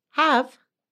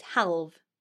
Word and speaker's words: Haf: How would you pronounce the name spelt H-A-F? – Have. – Nearly. It's halve. – Oh, that's really hard. – I don't know if that's even right how I'm saying it. It's Halv, actually.